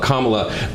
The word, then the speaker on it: Kamala: Kama, like the punctuation mark, look, Kamala.